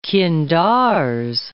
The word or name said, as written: qindars